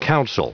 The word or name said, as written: council